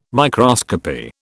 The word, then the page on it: microscopy: British English